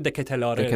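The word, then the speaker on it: De Ketelaere: دکتلر اوکی شارل دکتلر نه سخته البته با یه تلفظی که دکتلاره دکتلار آره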